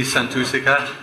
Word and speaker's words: Santussika: Okay, so welcome everybody to the Indus restaurant and for our special event with Bhikkhuni Santussika.